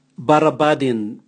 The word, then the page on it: barabadin: Raga